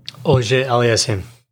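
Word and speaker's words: Auger-Aliassime: How do you say his last name? – Auger Aliassime.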